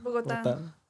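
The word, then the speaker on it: Bogotá: Is it Bogota or is it Bogota? – Bogota. – Okay. – Bogota. Accent on the A, right? – Bogota. – It's Bogo and then Ta, right? – Yeah.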